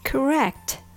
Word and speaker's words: Correct: 単語を2つに分けて小さな音の単位を学びましょう一度正しい発音ができるようになれば他の単語や文章に簡単に応用できます Correct starts with COR but in this case COR is pronounced cur and not core cur cur Make sure you don't open your mouth too much occur.